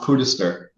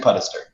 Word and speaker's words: Puddester: So usually it's pronounced Putister if people don't know it. But it's actually Putister.